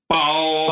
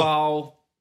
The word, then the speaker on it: Bao: Bao! – Bao, because its first tone means bag. – Okay. – Yeah? Is that helpful? – Just one sound for that one. – Yeah. – Bao!